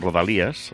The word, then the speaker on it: Rodalies: Pues mira, no lo hemos hecho adrede, con esto de Rodalies, que es como se escribe y se pronuncia en catalán Rodalías, las cercanías, pero que rima con regalías en español, el privilegio, mira tú qué cosas.